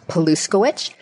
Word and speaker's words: Pluskiewicz: So for those of you taking notes, my name is Barbara Pluskiewicz, and Pluskiewicz is spelled the exact way it sounds, Pluskiewicz.